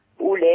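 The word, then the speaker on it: أولئك: ما ينفعش اقول اولئك اولئك، الواو اللي هي مرسومه دي بعد الهمزه لا تنطق. تمام تمام. حاضر. اولئك الذين اشتروا الحياه الدنيا بالاخره فلا يخفف عنهم العذاب ولا هم ينصرون.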